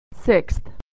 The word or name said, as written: sixth